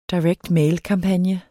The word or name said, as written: direct mail-kampagne